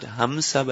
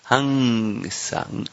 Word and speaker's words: Hansa: No, I'm saying it's not Hamsa, but Hansa. And like you pronounce it like NG. Hansa.